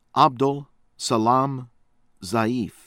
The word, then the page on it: Abdul Salam Zaeef: American English